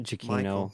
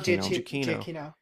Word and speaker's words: Giacchino: Giacchino. – Michael Giacchino.